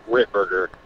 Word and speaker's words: Ripberger: My last name is Ripberger.